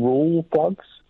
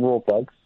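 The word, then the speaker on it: Rawlplug: The L, I'm pretty sure the L is silent. I'm pretty sure it's just rule plugs. – It's not. It's rule plugs. – I'm pretty sure that L is silent. It's just raw. If I go into a shop and say I need some raw plugs...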